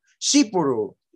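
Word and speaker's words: Tsipouro: Cipiru, Cipiru, Cipiru is how you pronounce it. Starts with a T, T-S, Cipiru.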